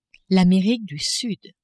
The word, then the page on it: Amerique du Sud: French